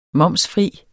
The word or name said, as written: momsfri